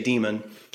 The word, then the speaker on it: daemon: When I, in the new book, right, the essay book, I did the Romanized spelling D-A-E-M-O-N, which I just say demon. When you have the D-A-I-M-O-N, I really don't know ancient Greek all that well.